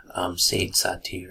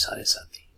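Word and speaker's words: Sade Sati: You might be pronouncing it as Sadesati or something, but the fact stands that the pronunciation, proper pronunciation, is Sarasati.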